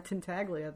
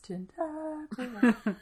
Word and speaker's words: Tintaglia: She's not gonna ride Tintaglia there, maybe. – You said it wrong. It's Tintaglia.